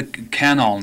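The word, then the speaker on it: Canol: And the official pronunciation was Canal. However, workers on that project dreamed up all sorts of variations so that you would hear canal, et cetera, et cetera, et cetera. But it was officially canals, each syllable equally stressed.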